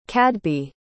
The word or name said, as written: cadby